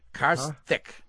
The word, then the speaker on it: Karthik: Car as in car, the automobile. – Yeah. – And thick as in thin and thick. Karthik. – Karthik. Huh? – That's right. – So C-A-R-T-H-I? – No, it's K-A-R-T-I-K.